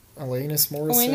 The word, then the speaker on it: Alanis Morissette: Billie Eilish's whispery vocals with the brashness of Alanis Morissette? Is that how you say her name? – Alanis Morissette. – Alanis Morissette and Avril Lavigne, which is, like, very funny, but I can see what they're, like, where they're picking all that up on.